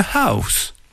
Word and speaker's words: house: I sleep in a house. Alors voilà les enfants, c'est un deuxième mot aujourd'hui avec un H au début. Ça vous entraîne sur la bonne prononciation. Le H.